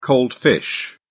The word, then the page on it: cold fish: British English